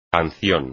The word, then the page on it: canción: espagnol